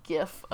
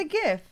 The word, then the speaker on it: GIF: It's a gif. – No, it's a gif. – It's the acronym stands for, is G for graphic. It's a hard G.